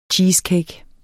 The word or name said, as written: cheesecake